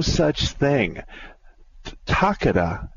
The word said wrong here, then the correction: Takata: I think that's how you pronounce it, Jeremy. Do you remember? Takeda? Or Takata. No, it's Takata.